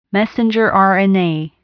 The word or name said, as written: messenger RNA